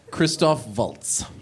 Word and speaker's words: Christoph Waltz: Christoph Waltz. – Yeah, give you bonus points for pronunciation.